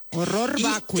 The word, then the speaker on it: horror vacui: Horror vacui. – Te voy a decir, a ver. Ajá, horror vacui. El horror vacui, o sea, pero se pronuncia horror porque está en latín.